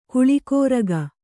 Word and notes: ಕಲಿಕಾರಕ: kalikāraka

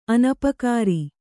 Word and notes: ಅನಪಕಾರಿ: Kannada

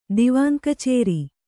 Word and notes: ದಿವಾನ್‍ಕಚೇರಿ: Kannada

